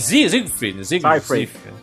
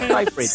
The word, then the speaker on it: Seyfried: Amanda Seyfried, muito bem. Siegfried. – Não, Siegfried é. – Eu pensei que era Amanda Seyfried. – É Seyfried. Seyfried. – Seyfried.